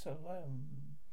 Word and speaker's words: Samhain: Halloween's origins date back to ancient Celtic festival of Samhain, pronounced Salam.